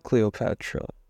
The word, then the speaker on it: Cleopatra: Cleopatra.